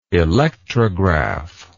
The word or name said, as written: electrographically